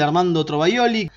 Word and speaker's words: Armando Trovajoli: Y Lunghi Journey de la Vendetta, de Armando Trovajoli. Así que, ¿lo dije bien? – Lo dijiste bien.